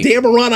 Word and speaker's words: Dameron: Daveron. – Poe Daveron. – With an M. – Daveron. – Like cuss word, Aron. Yeah. – Like Dameron, I got his name wrong.